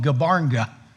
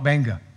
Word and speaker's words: Gbarnga: And it's spelled G-B-A-R-N-G-A. You'd think it would be called Gabarnga, but they call it Banga.